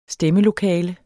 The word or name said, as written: stemmelokale